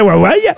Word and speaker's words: Huawei: Comment on prononce? – Huawei. – Huawei. – Huawei. – Ah ben voyez. – Huawei. – Qui c'est qui avait raison? C'est bien Huawei.